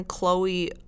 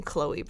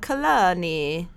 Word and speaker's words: Chloe: And she ended up just changing her name to Toni because no one at Howard could pronounce the name Chloe properly. – Really? – Yeah. They're like, Kalani.